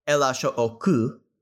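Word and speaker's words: L.H.O.O.Q.: So LHOOQ is pronounced in the French way LHOOQ which translates to El Asho Oku,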